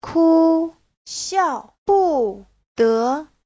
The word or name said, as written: ku1xiao4bu4de2